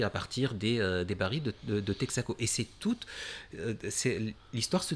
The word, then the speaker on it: Texaco: Et Texaco, c'est... alors je vais le prononcer à la manière martiniquaise, Texaco.